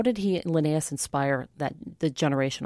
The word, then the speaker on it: Linnaeus: And at that time, botany had just gone through a major change where Linnaeus, is that how you say his name? – Yes, Carl Linnaeus.